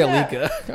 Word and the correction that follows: Aquila: I'm so embarrassed. – I thought that was just like a fancy way of... – No. I said it wrong.